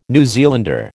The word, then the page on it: New Zealander: American English